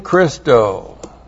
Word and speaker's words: Christo: you see en Christo. That's the dative form, Christo is Christos. But here's the preposition en, that's in Christo, C-H-R-I-S-T. And it's a long O because that's dative, but that's from Christos.